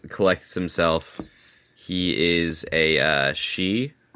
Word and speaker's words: sidhe: S-I with a little dash over it, D-H-E. The Celtic language, everybody. – Is it actually pronounced she, like woman? – No, that's actually how it's pronounced. Like the word banshee, if you actually spell it the Gaelic way, it's B-E-A-N space S-I-D-H-E.